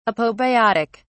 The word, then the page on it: apobiotic: British English